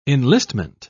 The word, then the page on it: enlistment: British English